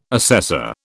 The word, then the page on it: assessor: British English